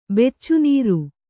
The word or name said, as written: ಬೆಚ್ಚುನೀರು